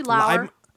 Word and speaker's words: Lauer: But the film in question, maybe you've heard of it, A Little Ditty, directed by Peter Lauer, or Lauer? How do you say that? – Probably Lauer. L-A-U-E-R. – Lauer, I think. – It's pronounced Laura. – Yep. Yeah, it's Lauer.